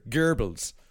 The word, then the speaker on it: Goebbels: Well, it's but I mean the actual pronunciation is even cuter. Goebbels.